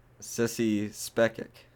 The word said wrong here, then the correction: Spacek: No, that's wrong.